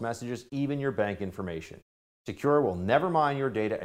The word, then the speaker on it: Sekur: That's why you need a new privacy and cybersecurity application tool called Secure. It is spelled S-E-K-U-R.